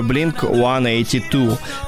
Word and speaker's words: Blink-182: Blink-182, правильно вимовляється, звісно, англійською Blink-182.